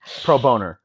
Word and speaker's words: pro bono: The one book that I did have, I still love to this day, is The Street Lawyer, which is about I think pro bono. Yes, did I say that right? – Pro bono. – Pro bono.